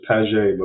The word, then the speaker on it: Paget: Actually, the French pronunciation is Paget, but we go by Paget.